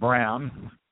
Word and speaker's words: Braun: Pronounced Brown. It's spelled Braun.